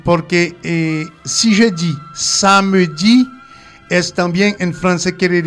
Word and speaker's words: samedi: S-A-M-E-D-I. Samedi. Samedi. Okay. Ça s'écrit samedi, pero se prononcia samedi.